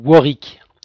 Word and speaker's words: Warwick: Alors c'est un faux ami, ça se prononce Warwick. – Warwick, Kevin Warwick. Warwick, désolé. – Ça s'écrit Warwick, effectivement.